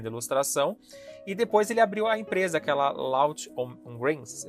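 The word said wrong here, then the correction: Laugh-O-Gram: é assim que se pronuncia? É Lawful Gram.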